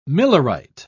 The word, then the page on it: millerite: British English